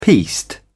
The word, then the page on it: piste: British English